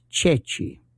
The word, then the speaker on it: ceci: One of them was that during the war, they would sometimes hold up a chickpea, and it's pronounced Ceci, C-E-C-I. But French could not pronounce that.